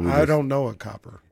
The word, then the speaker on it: Copper: Because I used to always say Cooper, and her name. – It's Copper.